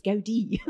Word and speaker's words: Gaudí: Oh, well, the name isn't actually Gaudí, it's Gaudí. So we've got Gaudí, God's architect.